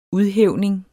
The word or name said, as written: udhævning